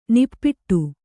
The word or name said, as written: ನಿಪ್ಪಿಟ್ಟು